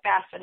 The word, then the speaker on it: FAFSA: And Jean, before we went to break, you know, you had mentioned FAFSA, which is the F-A-F-S-A, pronounced FAFSA, which I just learned today.